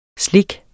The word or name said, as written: SLIKS